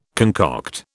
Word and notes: concoct: British English

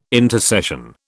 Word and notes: intercession: British English

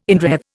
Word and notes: inwreathe: Indian

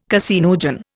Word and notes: caseinogen: Indian

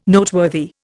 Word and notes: Noteworthy: Indian

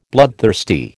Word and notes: bloodthirsty: American English

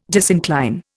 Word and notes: disincline: Indian

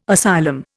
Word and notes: asylum: Indian

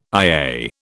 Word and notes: IA: British English

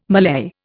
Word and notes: mallei: Indian

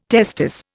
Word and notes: testis: Indian

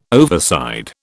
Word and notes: overside: British English

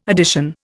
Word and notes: addition: Indian

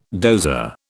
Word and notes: dozer: British English

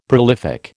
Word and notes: prolific: American English

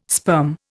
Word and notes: sperm: Indian

